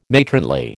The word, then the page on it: matronly: American English